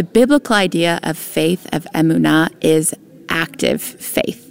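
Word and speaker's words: emunah (אמונה): Emunah, or emunah, more properly pronounced in Hebrew.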